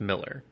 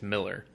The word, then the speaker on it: Millar: Because with Mark Millar, I know everyone, and I guess it's just pronounced Miller. So maybe we should correct ourselves. Mark Millar. – Huh? – Yeah. It's spelled A-R, but it's Miller.